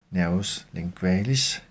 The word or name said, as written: n. lingualis